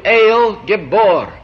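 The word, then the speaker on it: El Gibor (אל גבור): Now the second name is pronounced in Hebrew, El Gibor.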